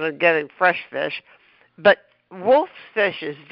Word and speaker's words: Wulf's: I'd never heard of it though, but tell us the fit, the wolf, spelled with a U, by the way, listeners, WULF'S, wolf's fish.